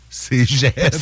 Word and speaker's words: Cégep: Je lisais ça, je l'ai prononcé. – Cégep. – C'est le Cégep, manifestement. – C'est un G? – Oui, c'est un G, okay.